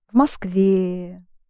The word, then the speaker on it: в Москве: Yes, and you keep the stress on the ending. Москва, в Москве, где.